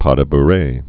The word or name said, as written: pas de bourree